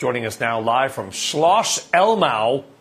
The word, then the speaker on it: Schloss Elmau: And by the way, you didn't butcher that name. Schloss Elmau was a very, very good pronunciation, Brian, as well. So 10 out of 10 on that one.